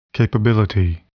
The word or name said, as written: capability